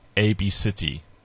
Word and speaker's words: ABCDE: But it's pronounced ABCity. – Ab-city. – Ab-city. – Yeah. – Okay. – So it's a bit of a trend in the US now. It's Ab-city, spelt ABCDE.